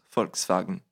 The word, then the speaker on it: Volkswagen: En voiture allemande, on a bien sûr Volkswagen qui se prononce Volkswagen.